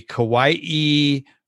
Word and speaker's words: Kauai: The Kauai Fatherhood Council is hosting a men's conference. Am I saying that correctly? – Yes, you are, sir.